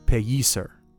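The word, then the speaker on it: Pellicer: So this is the more correct pronunciation of Pelliser. It's pronounced Pelliser.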